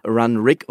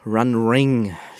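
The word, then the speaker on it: Runrig: Die heißen Run Rig und nicht Run Ring, wie ich es fälschlicherweise gesagt habe.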